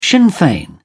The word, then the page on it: sinn fein: British English